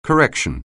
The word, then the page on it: correction: anglais